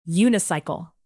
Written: unicycle

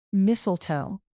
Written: mistletoe

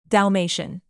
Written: dalmatian

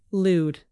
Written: lewd

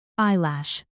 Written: eyelash

